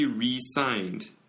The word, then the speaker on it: resigned: He re-signed 그리고 resigned Z 발음 나면 그러면 사직했다는 말이죠.